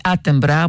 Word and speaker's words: Attenborough: Αναφέρεται στον David Attenborough ή Άτεμπρα, όπως σημειώνει με τη σωστή προφορά του ονόματός του.